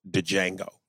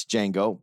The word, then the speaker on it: Django: And for the record, I always get shit because I pronounce it Django. – Yeah. – When the J is supposed to be silent.